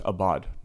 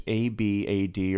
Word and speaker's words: abad: And so the word slaves here is abad. Now think in English, A B A D, or if some people pronounce it A V as in Victor, uh, but A B as in boy, A D as in dog, right? So abad. Later, we're going to see it change to abed, where the vowels go from A's to E's.